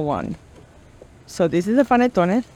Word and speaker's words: panettone: So here we have the il panettone di Milano.